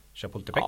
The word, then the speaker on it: Chapultepec: Club de Golf Chapultepec. Chapultepec. Ja, du får eh, fem av tio för det uttalet kanske. ja, inte inte helt perfekt kanske.